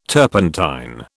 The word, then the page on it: turpentine: British English